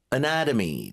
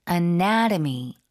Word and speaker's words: anatomy: Anatomy. 자, 해부학 발음 좀 도와드릴게요. – Anatomy. – 이음절 강세. – Anatomy.